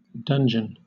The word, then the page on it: dungeon: British English